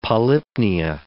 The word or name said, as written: polypnea